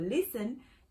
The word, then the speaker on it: listen: Pero listen es de escuchar, no lleva la T pronunciada.